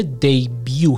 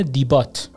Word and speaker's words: debut: دیبیو چجوری اسپل میشه؟ D-E-B-U-T، یعنی آخرش یه تی داره، وسطش هم یه نداره، ولی تلفظ میشه دیبیو، D-E-B-U-T، یعنی کسی این رو ببینه میخونه دیبات، در حالی که این دیبات تلفظ نمیشه، دیبیو تلفظ میشه.